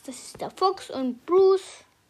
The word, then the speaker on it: Bruce: B-U-R-U-C-E. Bruce. Eben. Mit E. – Ja, aber das E am Schluss spricht man nicht.